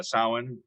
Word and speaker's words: Samhain: So you were saying Samhain, which is spelled Samhain. – That's correct. So, yes, that was a holiday that really got blended with winter solstice.